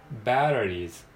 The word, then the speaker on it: batteries: バテリーズ、バテリーズ、バテリーズ。